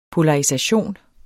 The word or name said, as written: polarisation